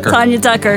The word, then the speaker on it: Tanya Tucker: It rhymes with Rania Rucker. – There you go. There you go. Glenn Campbell's ex. – There you go. – Tanya Tucker.